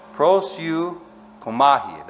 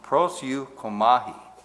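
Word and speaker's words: proseuchomai: He says, the word pray, and hopefully I can pronounce this in the Greek, is prosyukomai. Prosyukomai. That's how you pronounce it. Prosyukomai.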